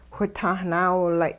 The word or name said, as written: whitahnaawilay'